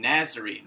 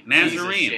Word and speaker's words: Nazarene: Is it Nazarene? Like tangerine? – Yeah. No, not tangerine. Nazarene. – Nazarene.